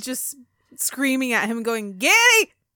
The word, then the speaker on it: Geddy: Geddy's original name was Gary. However, due to his mother's heavy accent, she pronounced his name Geddy. – Geddy. – So it's Geddy Lee, but it's supposed to be Gary Lee.